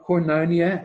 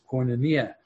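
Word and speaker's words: koinonia (κοινωνία): Not koinonia, as people sometimes call it, but the Greek pronunciation is koinonia.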